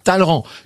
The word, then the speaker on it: Talleyrand: Ça s'écrit Talleyrand, mais ça se prononce Talleyrand. Alors, c'est ce qu'on appelle les prononcés de cour.